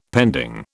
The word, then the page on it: pending: British English